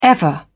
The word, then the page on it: ever: British English